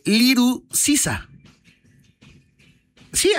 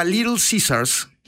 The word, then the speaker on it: Little Caesars: Y de hecho ya empezó con una campaña padrísima visual y fonéticamente hablando, porque se dieron cuenta que la gente no pronunciaba Little Caesars, sino pronunciaba Little Caesar.